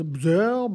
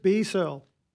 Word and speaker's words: Bsearl: It's spelled B-S-E-A-R-L. So I'm like, what is Bzerl, blah, blah, BSL? But no, apparently his full government name is Brandon Searle. So it's literally just B Searle.